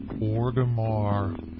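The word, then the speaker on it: Orgrimmar: And it is pronounced Orgamar.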